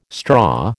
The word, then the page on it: straw: American English